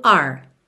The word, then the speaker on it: our: ele acaba virando are. R, ou seja, como a letra R mesmo, ok?